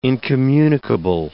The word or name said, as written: incommunicable